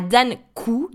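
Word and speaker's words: Dan Koe: je pense notamment à Dan Kou, donc ça s'écrit K o e euh,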